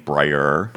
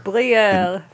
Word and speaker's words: Breyer: That he adopted the French spelling and pronunciation of his name, Breyer. – Breyer, forevermore. It's like brie cheese.